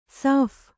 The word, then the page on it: ಶಫ: śapha